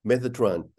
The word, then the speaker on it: Metatron: Metatron? – Metatron.